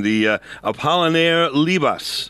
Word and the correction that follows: Labat: And, yeah, Apollinaire Labat. – There we go, Labat, thank you.